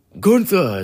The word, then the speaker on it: Gunther: Well, Gunther is how he pronounces it.